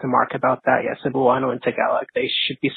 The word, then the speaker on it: Cebuano: He's saying Cebuano. – Cebuano.